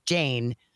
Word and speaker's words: Jane: And I got Jane, which is great because it rhymes with pain and insane and all those great things.